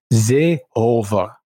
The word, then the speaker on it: Seehofer: Nou, hij zei, mijn inziens is dat niet heel moeilijk om te weten dat je dat niet op zijn Engels, maar op zijn Duits moet uitspreken. Nou, daar ben ik het best wel met Johan eens. Dus het moet zijn Seehofer.